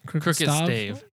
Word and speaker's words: Crooked Stave: we had this Earl Grey tea from Crooked Stav. Is that how you pronounce that place? – Crooked Stave.